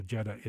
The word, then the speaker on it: Jedha: So Jedha is another planet in Rogue One. It's spelled J-E-D-H-A.